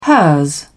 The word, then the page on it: hers: British English